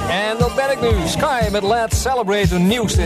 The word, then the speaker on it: Sky: Het is niet Skyway, zoals in Nederland door sommige discjockeys wel eens gezegd wordt. Maar het is inderdaad gewoon Sky.